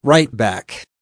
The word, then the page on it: right back: American English